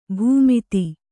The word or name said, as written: ಭೂಮಿತಿ